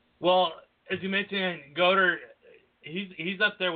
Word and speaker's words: Goedert: Goedert. – Oh, Goedert, yeah.